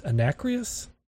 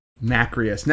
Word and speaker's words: nacreous: Anacreous? – Nacreous.